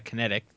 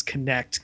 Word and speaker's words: Kinect: And I'm going to keep arguing that it's Kinect because it's spelled K-I-N-E-C-T. – Yeah, you're probably right. But it just doesn't make any sense. Because I figure like kinetic energy.